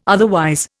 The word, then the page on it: otherwise: Indian